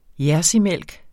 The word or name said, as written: jerseymælk